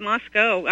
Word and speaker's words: Moscow: Well, just like Moscow. It's not Moscow. It's Moscow.